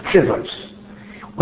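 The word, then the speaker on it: Tzivais (צבאות): Now here the word Tzivais is the same letters as Tzavakis. However, the pronunciation is instead of Tzavakis, it's Tzivais.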